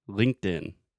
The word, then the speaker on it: LinkedIn: So the correct pronunciation, LinkedIn.